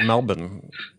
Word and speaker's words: Melbourne: Melbourne, Australia. – That's it. – Yes. There's no R in Melbourne, despite how you see it spelled.